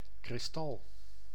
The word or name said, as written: crystal